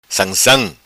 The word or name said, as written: Sansam